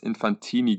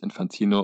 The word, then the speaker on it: Infantino: nee, du hast Infantini gesagt. Ah, uff. la Panini-Sticker. Ähm, aber er wird, Namen. – Genau. Aber er wird ja natürlich Infantino, unser geliebter Mann, ausgesprochen. ähm, Korrupt.